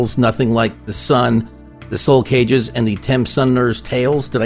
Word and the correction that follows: Summoners: The Ten Summoners' Tales.